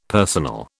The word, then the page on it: personal: British English